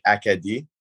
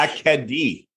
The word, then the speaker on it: Acadie: Just say it Acadie or Acadia, because it's spelled Acadie. – Yeah, like it's like, I think it's French, so it's like Acadie, but in English. Oh, Acadie.